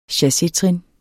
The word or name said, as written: CHASSETRIN